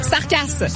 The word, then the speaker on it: Sarcass: Écrit presque comme ça se prononce, Sarkasse. S-A-R-C-A-S-S.